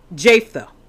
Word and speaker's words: Jephthah: Jephthah.